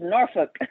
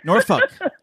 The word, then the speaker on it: Norfolk: Norfolk. Did I say it right? Or how do you say it? – It's Norfolk. – Norfolk. Almost like you're saying the bad word.